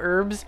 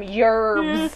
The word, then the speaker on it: herbs: There's a YouTuber that I like, and she's English, and she did a video where she's talking about herbs, and she's like, here in the UK we pronounce the H, so yes, I call them herbs. I know that in America you call them herbs. – I call them yerbs with a Y.